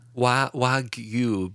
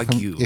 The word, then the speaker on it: Wagyu: Yeah, is that how you say it? Wagyu? – Wagyu beef. – Yeah, Wagyu. It's from Japan.